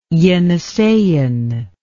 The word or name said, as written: Yeniseian